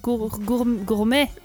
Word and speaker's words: gourmet: Bon, yashiki Manga peut-être, mais euh, il y en a, si je vous dis, les Gourumés. Les ah. gourmets, prononce-le très vite. gourmet.